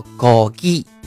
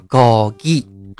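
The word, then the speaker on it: 高 鸡 (고기): 你比如说，今天大仙教大家说一个词啊，叫“ 高鸡”，发音啊，“ 高鸡”。什么是高鸡呢？高鸡是肉啊，就是我们吃的像猪肉啊，就是猪肉就是带鸡高鸡，然后牛肉啊烧高鸡啊这种东西，嗯，所以说你学会高鸡了之后呢，你就会学很多啊，像之前说的猪肉啊带鸡高鸡，牛肉是 sogogi 啊。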